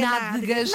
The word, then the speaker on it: nádegas: Atalhos, mas de facto a palavra correta é nádegas. Nádegas. É mais difícil. Nádegas. – Tem mais uma sílaba, exatamente.